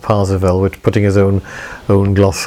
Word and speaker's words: Parsifal: So it's Percival in German. They pronounce it Parsifal, and that's where we get Wagner's opera Parsifal from.